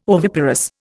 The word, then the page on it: oviparous: Indian